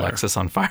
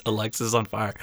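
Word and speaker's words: Alexisonfire: It's Alexis on fire because it actually says pronounced Alexis on fire.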